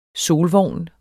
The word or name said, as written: SOLVOGNEN